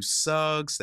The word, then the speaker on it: Suggs: you can follow me at TW Suggs. That last name is spelled S-U-G-G-S.